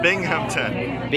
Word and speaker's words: Binghamton: Hey, this is Tim Nordwin from the band OK Go, and you're listening to WHRW Binghamton. Is that right? – Binghamton. – How do you say it? – Binghamton. Yeah. – Oh, I spelled it wrong. How do you spell it? – B-I-N-G.